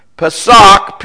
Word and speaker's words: Pesach: 16, the Hebrew word for Passover is Pesach. I'll spell it for you. P-E-S-A-C-H, Pesach.